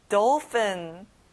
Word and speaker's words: dolphin: PH는 F 발음이 나요. dolphin.